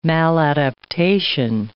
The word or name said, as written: maladaptation